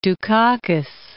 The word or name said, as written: Dukakis